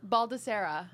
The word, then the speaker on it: Baldesarra: Baldessera.